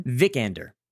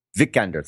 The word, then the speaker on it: Vikander: And Alicia Vikander, is that how you say her name? – Vikander. – Vikander, thank you.